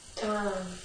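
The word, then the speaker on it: Tom (ཏཾ): Tom. And the ah stretches for a long time. So it's like a song. It starts with a t, but then it just goes ah.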